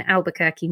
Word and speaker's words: Albuquerque: It should have been in Albuquerque, New Mexico.